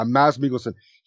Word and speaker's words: Mads Mikkelsen: Yeah, Mads Mikkelsen.